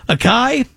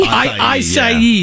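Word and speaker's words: açaí: Is it a K or a Kai? I think it is a Kai, right? – No, the acai. – Acai, yeah. That's acai, how you say it.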